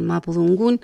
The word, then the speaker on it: mapudungún: y en este caso el mapudungún.